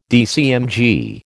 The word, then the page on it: DCMG: American English